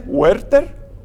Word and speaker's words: Huerter: Que no sé cómo se pronuncia en español. – Huerter. – Sin la H, Huerter.